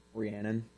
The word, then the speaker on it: Rhiannon: Rhiannon.